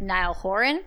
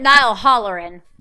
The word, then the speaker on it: Niall Horan: It I like Niall Horan. I especially like not I said it right that time. You called him Niall Hollerin because why not? That's really what she thought his name was.